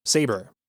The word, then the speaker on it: Sabre: Perhaps the funniest moment for me in the whole episode is, you know, the cold open, they refer to the company's new owners as Sabre, spelled S-A-B-R-E. And we don't know what's wrong until Gabe shows up and pronounces it correctly, Saber.